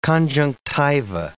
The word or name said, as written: conjunctiva